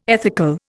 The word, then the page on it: ethical: Indian